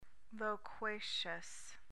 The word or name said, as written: loquacious